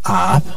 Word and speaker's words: Ahab: Poco después, Ahab, se escribe A-H-A-B. Yo lo pronuncio así porque se supone que la H es muda, Ahab, o bien a veces se le da la entonación de una J, Ahab. Se los dejo a su consideración.